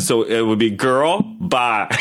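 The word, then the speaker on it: Jørrel: So when I was in Norway this past year, I met somebody, and her name was Girl, and it's spelled J with an O and a slash the O, R R E L. Girl.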